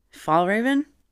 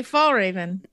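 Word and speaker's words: Fjällräven: Fall Raven? – Yes. Is that it? – Yes. – Okay, Fall Raven. That was easier than I thought. – I'm pretty sure the J is silent.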